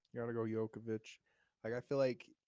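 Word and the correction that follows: Jokić: Did I butcher the name of it? Jokic. Jokic.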